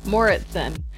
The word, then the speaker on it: Mouritsen: Mouritsen.